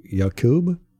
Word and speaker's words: Yaqub: Aqib Talib, his brother, uh, Yaqib, ya- Yaqub, Y A Q U B.